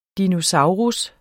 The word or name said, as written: DINOSAURER